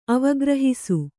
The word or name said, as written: ಅವಗ್ರಹಿಸು